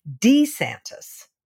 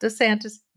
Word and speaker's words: DeSantis: Apparently, he has gone back to the original pronunciation of his name, which is DeSantis, not DeSantis, DeSantis.